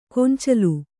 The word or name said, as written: ಗೊಂಚಲು